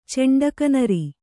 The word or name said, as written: ಚೆಂಡಕನರಿ